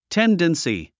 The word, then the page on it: tendency: téndənsi; テンデンスィ